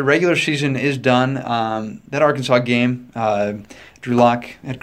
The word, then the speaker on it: Arkansas: So how do you pronounce it? – Arkansas. – Oh, okay. – Don't say that. Brett Bielema will get really mad at you if you say it the other way.